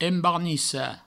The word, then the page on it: embarnicar: embarnissa; Provençal